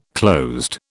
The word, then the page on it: closed: British English